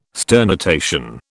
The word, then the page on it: sternutation: British English